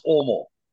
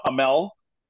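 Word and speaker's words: Åmål: So it's pronounced Omol. – Yeah. – A M A L. And so for the North American, it's not Amel, it's Omol.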